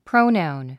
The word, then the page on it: pronoun: próunàun; プロウナウン